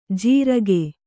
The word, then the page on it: ಜೀರಗೆ: jīrage